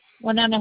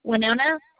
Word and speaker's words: Winona: Winona Halbert. – I'm sorry, can you repeat that? – Winona Halbert. – Can you spell that for me? I'm not finding an account. – Okay. Winona, W-I-N-O-N-A, last name Halbert, H-A-L-B, like boy, E-R-T.